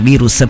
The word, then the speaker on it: Virus: lo posso pronunciare all'inglese, ma no, lo pronunciamo in italiano, Virus, proprio perché quest'album è stato concepito durante la quarantena dello scorso anno.